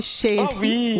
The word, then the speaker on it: Sherry: Et Marilyn Sherry, est-ce que je prononce bien votre nom de famille? Est-ce que c'est Sherry? – Oh, oui. Oui, en français, Sherry, en anglais, Cherry.